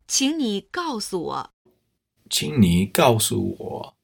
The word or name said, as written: qing ni gaosu wo